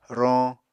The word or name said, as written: roñ